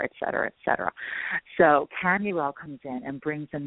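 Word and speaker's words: Chamuel: Okay, Tamuel, some people pronounce it Shamuel, but it's C-H-A-M-U-E-L. I pronounce it Tamuel because I also work with another angel that's S-H, and that I call Shamuel.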